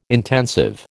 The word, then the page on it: intensive: American English